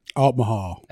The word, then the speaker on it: Altamaha: Pull it up, but you're looking at the Altamaha River at Doctortown. – Yeah. – It's not how did you pronounce it? – I was there last weekend. – Altamaha. – He said it just like that, but yeah.